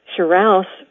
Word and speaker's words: Shirouse: Hello, my name is Diane Anton Shirouse. Shirouse rhymes with house and is the name of my husband's family from Alsace-Lorraine in France.